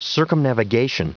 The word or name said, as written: circumnavigation